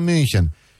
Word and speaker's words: München: Și noi zicem München. Și totdeauna când auzeam München.